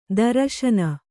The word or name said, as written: ತ್ರಸ್ನು